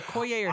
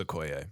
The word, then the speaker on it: Okoye: Is it Inoke? The head of the Okoye. – Thank you. It's either Okoye or Shuri. – I forgot about Okoye. I would love it if it was Okoye.